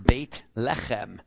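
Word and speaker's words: Beit Lechem (בית לחם): But in Hebrew, it's pronounced Beit Lechem.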